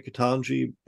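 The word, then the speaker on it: Ketanji: Katani Brown Jackson? – Yeah. – Is it? Did I just say her name? Yeah. Katan. Oh, now you've got me wondering. Katana Brown Jackson, right? Katanji Brown Jackson.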